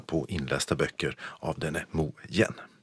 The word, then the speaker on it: Mo Yan: Och Nobelpristagaren stavar sitt namn M-O-Y-A-N och det ska tydligen uttalas Mojen.